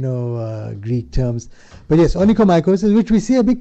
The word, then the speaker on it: onychomycosis: Look, in practice, which I see very, very commonly and very, very poorly treated, onychomycosis, I use the correct term of pronunciation. – Yeah, onychomycosis.